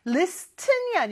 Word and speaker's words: listen: Listen이 아니고요. 여기는 발음은 listen. T 발음이 발음이 안 나요.